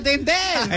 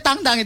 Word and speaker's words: Tintin: Tintin tuh Tintin. Eh enggak enggak, Tintin tuh tang tang aslinya. Ah, serius. Stawa. Aslinya tuh Herge itu Adventure of Tang Tang.